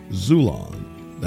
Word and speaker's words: Xulon: Get your paperback copy on Amazon, Barnes & Nobles, and from my publisher, Zulon. That's spelled X-U-L-O-N.